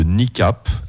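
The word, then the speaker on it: Kneecap: On a démarré avec le morceau d'introduction de l'album de NICAP, album qui s'appelle Fine Art et qui est sorti en 2024.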